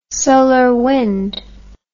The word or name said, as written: solar wind